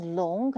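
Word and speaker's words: long (龍): So next time, when you look at that character, you know that it's pronounced long, correct?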